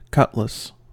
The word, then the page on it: cutlass: American English